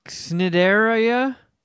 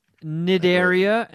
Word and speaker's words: Cnidaria: Sea anemones are classified in the phylum Cnidaria. – Sea is silent. – Cnidaria, and the class is Anthozoa, and the subclass is Hexacoralia.